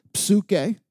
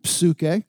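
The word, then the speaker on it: psuche (ψυχή): This word is pronounced psuche. It's where we get our English word psyche. We decided to drop the P, but in Greek it's pronounced psuche.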